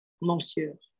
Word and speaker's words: monsieur: So no monsieur. O. So no O, it's kind of like me or like this.